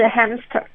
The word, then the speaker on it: hamster: 对，像仓鼠的英文是 hamster，嗯，那那个 ham 的话，你就要加重发音。